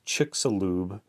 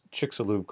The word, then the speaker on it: Chicxulub: So um, upon lo- looking it up, we find that supp- according to scientists, supposedly this Chicxulub, it's C H I C X U L U B Chicxulub crater. Uh, that's how they want you to spe- uh, pronounce it anyway.